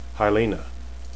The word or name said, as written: hailene